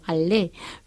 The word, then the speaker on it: aller: Tampoco se pronuncia la del verbo aller, que también acaba en R en el infinitivo, aller, pero no es de la primera conjugación este verbo a pesar de terminar en R.